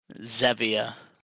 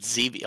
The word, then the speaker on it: Zevia: Wait, you definitely pronounced that wrong, no? – Did I? – Just Zevia. – Oh, is it Zevia? Oh, because they use stevia.